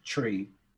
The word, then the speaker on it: Twi: It's spelled T-W-I, but it's pronounced Tri.